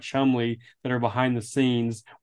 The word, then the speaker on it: Cholmondeley: So there's like guys like we keep saying Charles, but his last name is Chumley, but it's pronounced Chumley, but it's spelled.